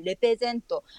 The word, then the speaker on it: レペゼン: レプリゼントが、まあ、ある意味ちょっとこう日本語的に発音されてレペゼントというようになったという経緯があります。